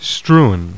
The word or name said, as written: STREWN